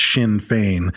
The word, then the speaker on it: Sinn Féin: Fucking Chinese fucking But it's not, but it's spelled, it is not spelled in. – It must be like, Gaelic. – No, it's S-I-N-N, and then the second word is F-E-I-N, but it's Sinn Féin.